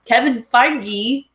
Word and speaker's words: Kevin Feige: I mean, Kevin Feige, I said that right? – You did!